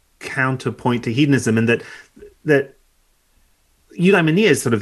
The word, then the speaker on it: eudaimonia: and that word is eudaimonia. – Yeah. – Which I'm not even entirely sure at this point I'm still pronouncing. – No, correctly. That's right.